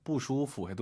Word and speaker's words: 不舒服: 그냥 不舒服 해도 좋아요. 不舒服. 아, 요것도 많은 분들께서 슈프, 슈프 피읍 발음이 나와요.